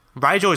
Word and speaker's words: Rigel: and uh, because Rigel is pretty much just Nigel with an R, yeah, when I met you, it's how you told me to say it.